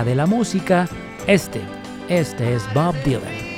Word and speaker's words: Bob Dylan: Sí lo dije bien, es Bob Dylan.